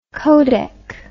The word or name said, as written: codec